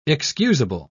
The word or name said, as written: excusable